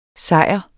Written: sejr

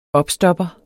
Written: opstopper

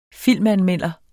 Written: Filmanmelder

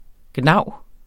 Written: gnav

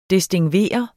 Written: distingveret

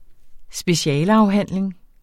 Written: specialeafhandling

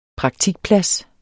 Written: praktikplads

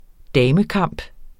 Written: damekamp

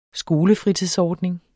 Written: SKOLEFRITIDSORDNING

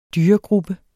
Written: dyregruppe

